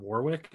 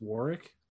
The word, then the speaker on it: Warwick: And his name is not Warwick, it's Warwick. – Oh, it's like W? Jessica Hennick. – Yeah, there's. Is that how you say her name? – Yeah. – Whoa, I did not know that. It's exactly, yeah, that's exactly yeah. The case. The W is silent, or so it's Warwick Davis.